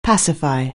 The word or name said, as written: pacify